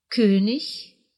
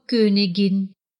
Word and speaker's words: König: Сравните. «König» – «король», но «Königin» – «королева».